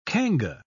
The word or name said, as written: Kanga